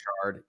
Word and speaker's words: Burchard: I don't know how to say his last name. – Burchard. Yeah.